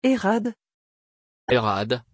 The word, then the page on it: herrad: French